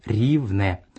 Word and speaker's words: Ривне: Просьба дальше при получении от меня мейлов называть его так, как и написано «Ривне», читается «Ривне».